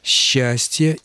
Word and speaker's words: счастье: А вот по поводу «считанной» и то же самое касается глаголов «счесть», и всевозможных других примеров, которые вы приводили, правила орфоэпии требуют, чтобы сочетания, передаваемые буквами «с» и «че», стоящими рядом друг с другом, читались как «ще». Правильное произношение «считанный», «счесть», «счастье» и так далее.